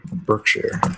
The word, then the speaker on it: Berkshire: Well, look up Berkshire Hathaway. Mm-hmm. Seriously, a couple stock quotes for that. – I'm going to do it. Berkshire. Is it like Worcestershire? – Kind of like Worcestershire. Yeah. Berkshire.